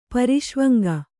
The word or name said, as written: ಪ್ರಸವಾಂಗ